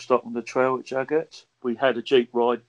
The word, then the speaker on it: Jagat: Jagat. – Yeah. I'm going to take your word on all these pronunciations. – J-A-G-A-T.